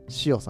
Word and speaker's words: SIO: しおさん、 SIO と書いて、しおですね。